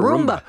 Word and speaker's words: Roomba: So I had this idea to buy Carol a Roomba. – Mm-hmm. – Is that how you say it? – Roomba.